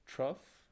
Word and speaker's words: trough: O-U-G-H. – And I think that's all of them. – Is it trough? – Trough rhymes with cough.